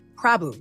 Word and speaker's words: Prabhu: Prabhu, yes.